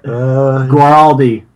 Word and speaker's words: Guaraldi: Guaraldi.